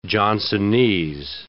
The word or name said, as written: Johnsonese